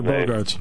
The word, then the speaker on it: Bogaerts: What you learned is Mookie Betts and the shortstop, I can never pronounce Xander Bogaerts.